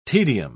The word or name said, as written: tedium